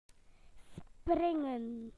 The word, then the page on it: to jump: British English